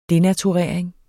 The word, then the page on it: denaturering: ˈdenatuˌʁεˀɐ̯eŋ; denatuˈʁεˀɐ̯eŋ